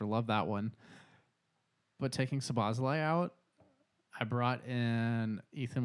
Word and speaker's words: Szoboszlai: And the craziest part about that is that I always say his name wrong, but I took Szoboszlai out. Actually, I said that right, Luke? – Good job there.